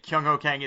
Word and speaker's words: Kyung Ho Kang: And by the way, you nailed it. Kyung Ho Kang is exactly how you say it.